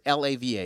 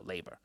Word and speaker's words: laver: L A V A. Is it lava? Lava? How do you pronounce that? There you go, laver. So then they'll see the laver.